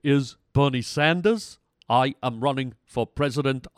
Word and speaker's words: Sanders: Could you please pronounce the name Sanders instead of Saunders? I've been listening to you for years now, and you've been pronouncing Bernie Saunders and Colonel Saunders from KFC, and it really irritates me.